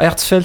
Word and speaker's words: Hertzfeldt: Alors, Herzfeld, c'est ah. écrit H-E-R-T-Z-F-E-L-D-T.